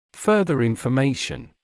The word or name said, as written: further information